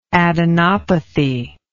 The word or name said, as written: adenopathy